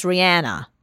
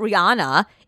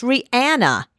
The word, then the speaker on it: Rihanna: I learned very early on working in music that it's Rihanna And people would say to me, what do you say it like that for? I said, because that's how you pronounce her damn name. It's not Rihanna. It's Rihanna. Anna. A-N-N-A. Ask her. Listen to her pronounce her own name.